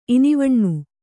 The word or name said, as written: ಇನಿವಣ್ಣು